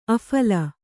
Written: ಅಫಳ

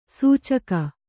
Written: ಸೂಚಕ

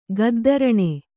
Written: ಗದ್ದರಣೆ

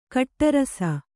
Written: ಕಟ್ಟುರಸ

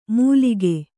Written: ಮೂಲಿಗೆ

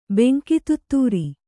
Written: ಬೆಂಕಿತುತ್ತೂರಿ

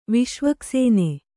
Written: ವಿಷ್ವಕ್ಸೇನೆ

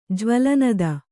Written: ಜ್ವಲನದ

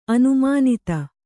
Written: ಅನುಮಾನಿತ

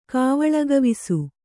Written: ಕಾವಳಗವಿಸು

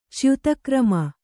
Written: ಚ್ಯುತಕ್ರಮ

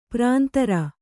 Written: ಪುರಂದರ

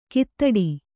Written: ಕೈತಟ್ಟು

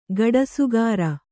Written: ಗಡಸುಗಾರ

